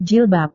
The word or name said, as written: jilbab